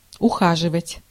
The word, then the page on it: solicit: /səˈlɪsɪt/